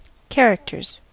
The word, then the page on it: characters: American English